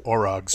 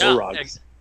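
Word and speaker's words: Orog: Orogs. – Yeah, Orogs.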